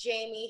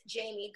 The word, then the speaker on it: Jaimi: Hey guys, it's Jamie here. You can find me on Twitter at It's Jamie Gray, or better yet, on Instagram at Jamie Gray. My name is spelled J-A-I-M-I.